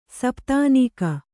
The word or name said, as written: ಸಪತ್ನೀಕ